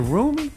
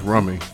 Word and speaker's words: Rumi: Is it Rumi or Rume? – So that's pronounced Rummy. – Rummy. – Okay. Same spelling.